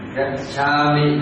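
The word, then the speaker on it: gacchāmi: Còn khi mà mình đọc mà đọc là Gacchami âm là qua đi, thành ra là đọc nhẹ cái âm mi này thôi, chứ đừng có nhấn mình cái mi cả cả là nhấn mình cả một kéo dài ra một chút.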